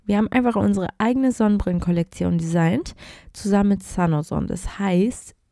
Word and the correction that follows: Sun Ozon: ihr, äh, sorry, falsch ausgesprochen, San Ozon.